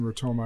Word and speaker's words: Rotoma: What is the meaning or ideology behind Rotoma? I know you told me it rhymes with Sonoma, but yeah.